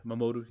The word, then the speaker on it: Mamoru: Yeah, Mamoru.